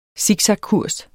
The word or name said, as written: SIKSAKKURS